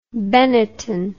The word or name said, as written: Benetton